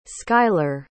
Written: schuyler

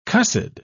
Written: cussed

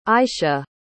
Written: aysha

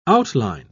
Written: outline

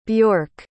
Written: bjork

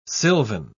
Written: sylvan

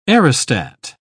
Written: aerostat

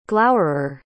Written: glowerer

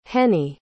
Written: Hennie

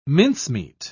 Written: mincemeat

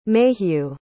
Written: mayhew

